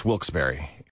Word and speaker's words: Wilkes-Barre: The only way to pronounce Wilkes-Barre.